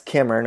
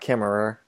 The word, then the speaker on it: Kemmerer: That's Kimmer, not Kimmerer. Apologies for years of saying it wrong. Although I don't know how we're supposed to ignore that last ER.